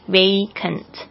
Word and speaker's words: vacant: 结果我刚才很快速的查一下字典呢，它是念 A 的声音没有错，就是老师刚才那个发音是对的，vacant。